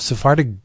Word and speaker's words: Sephardic: I have no idea that that uh, you know Sephardic Judaism.